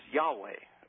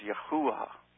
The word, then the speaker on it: Yahweh: And perhaps the most important name, most important of all the names, is Yahweh, or as Professor George Wesley Buchanan believes, it should be pronounced Yahuwah, as a breath almost coming out. But Yahweh is okay because the Orthodox Jews refer to, when they mean Yahweh, they refer to him as Hashem, which is Hebrew for the name.